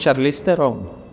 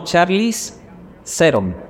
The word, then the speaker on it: Charlize Theron: Nosotros decimos Charlize Theron. Y no, y ella le dice como Charlize Theron. Ella lo pronuncia como debe ser, uno es el que lo pronuncia más mal que el que recibe.